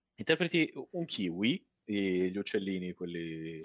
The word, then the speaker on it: Keywe: Il gioco si chiama Kiwi. scritto K-U-E. Ed è un gioco... – La vuole dare, no? – Sì, esatto, K-U-E. – K-U-E. – K-U-E. – Ma come? – Kiwi, si legge Kiwi. – Ma... – Sì. Ed è un gioco... – K-W-E-E? – K-E-Y-W-E.